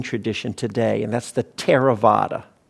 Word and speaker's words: Theravada: it's spelled Theravada, but it's pronounced Thera.